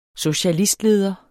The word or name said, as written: socialistleder